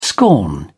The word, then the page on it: scorn: British English